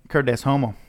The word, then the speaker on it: Cur Deus Homo: Did you pronounce that right? – Cur Deus Homo. Yeah.